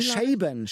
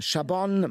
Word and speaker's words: Chabon: Skurril und schlau spannend von Michael, wie wird er wohl ausgesprochen? Chabon. Chabon, Michael Chabon.